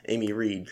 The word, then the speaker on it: Amy Reid: This is Amy Reed, correct? Is that how you pronounce Yes.